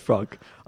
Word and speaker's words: Franck: They kept calling him Frank on the broadcast, and I'm like, there's no way that Franck it is not. – From Cameroon is named Frank. – No, it is pronounced Franck. He fouled out,